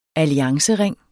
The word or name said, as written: alliancering